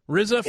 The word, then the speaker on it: RZA: Is it Raza? Is that how you pronounce his name? – RZA, yeah.